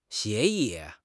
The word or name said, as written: 协议